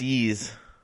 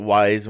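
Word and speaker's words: Ys: It's pronounced Ease. I always called it Wise when I was younger. – I was look at it and be like Wise. – Yeah, it's letter Y and an S. – Yeah, it's Japanese pronunciation. It's Y's.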